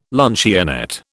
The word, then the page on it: luncheonette: British English